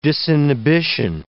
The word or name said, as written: disinhibition